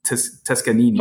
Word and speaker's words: Toscanini: Toscanini. Toscanini. Toscanini. You pronounce every syllable.